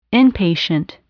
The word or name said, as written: inpatient